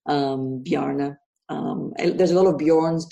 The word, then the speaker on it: Bjarne: So his name is spelled Bjarne. And the J is a silent. So it's Bjarne. And people call him Barney, Bjarne.